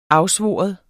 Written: AFSVOREN

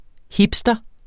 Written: hipster

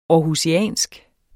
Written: aarhusiansk

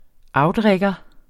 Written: outrigger

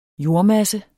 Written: jordmasse

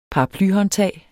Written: paraply

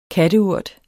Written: katteurt

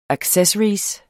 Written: ACCESSORY

